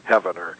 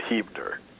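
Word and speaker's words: Heavener: I originally called it Hevener because it's spelled like that, Heaven, E-R, Hevener. But they told me, somebody called me up on the radio and told me they pronounce it Hivener.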